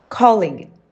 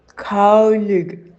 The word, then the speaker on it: colleague: Right pronunciation, calling, calling. Common mispronunciation, colleague. Colleague has unusual spelling because it is borrowed from French. So when people see it written down, they often assume it has a soft G sound, like the words village or privilege, but it doesn't.